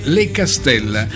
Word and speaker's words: Le Castella: L'ho detto bene, Le Castella, non l'ho detto alla francese questa volta, Le Castella di Isola di Capo Rizzuto Calabria.